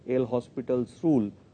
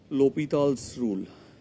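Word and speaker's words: L'Hôpital's rule: Though in India we will call it L'Hospital's rule, but if you go by the French pronunciation, it is called L'Hopital's rule.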